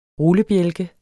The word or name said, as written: rullebjælke